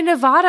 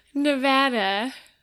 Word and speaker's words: Nevada: How do you say I say Nevada, and I know that's wrong, so I need to say it properly. – Nevada.